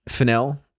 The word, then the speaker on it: Fennell: I want to say that's how you pronounce her last name. – Fennell, yeah. – Fennell.